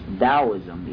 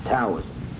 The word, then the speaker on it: Taoism: In fact, that's pronounced Taoism, even though it looks like it should be pronounced Taoism.